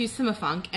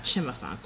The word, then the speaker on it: Cimafunk: So then we came to Simafunk, and I hope I am saying that correctly, and that it's not Chimafunk or Kimafunk. It's Cimafunk. – No, I think it's Sima. – I think it's Simafunk too.